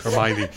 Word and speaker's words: Hermione: Because it rhymes with Heine? – Yeah. Yeah. – Rhymes with shiny? – Yeah, shiny Heine. Hermione.